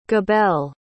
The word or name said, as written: gabel